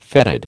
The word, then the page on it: fetid: American English